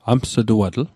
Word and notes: ams'tuatl: Mi'gmaq

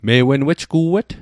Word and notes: me'_wen: Mi'gmaq

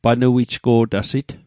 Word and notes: panuijgo'tasit: Mi'gmaq

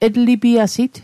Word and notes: etlipiasit: Mi'gmaq

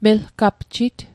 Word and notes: melgapjit: Mi'gmaq

